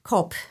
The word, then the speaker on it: Kopp: Ich muss da nicht, ja, okay, sagen Sie mir Name, Kopp, ja, wie schreibt man das? K-O-P-P.